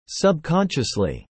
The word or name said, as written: subconsciously